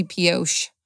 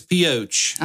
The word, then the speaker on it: Pioche: I feel like it should be Pioche. I want to put make that a SH instead of a CH. – No, it's POH.